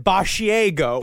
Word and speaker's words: Basciago: He goes, it's spelled as Basciago. It's spelled like a mixture of barf and Asiago, the cheese. But it is, he says it Basciago.